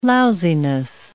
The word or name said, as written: lousiness